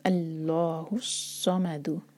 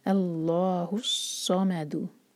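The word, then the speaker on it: अल्लाहुस्समद (اللهُ الصَّمَد): तो अल्लाहुस्समद, है को सौ से जोड़ेंगे, हा के बाद जो हमज़तुल वसल है वो प्रोनाउंस नहीं होगा और उसके बाद है लाम शमसिया, अल्लाहुस्समद। तो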